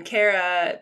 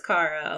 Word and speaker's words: Kara: And then Kara becomes Kara a lot of times.